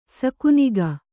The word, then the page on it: ಸಕುನಿಗ: sakuniga